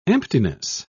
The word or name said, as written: emptiness